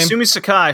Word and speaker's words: Sumi Sakai: Sumi Siaki? Is that how you pronounce her name? – Sumi Sakai from JD Star.